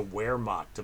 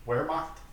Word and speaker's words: Wehrmacht: I mean, they were like the Wehrmacht of the plains. – Wehrmacht? – Wehrmacht. I'm sorry. Oh, I'm sorry, Herr Grimm, that I did not pronounce the W like a V.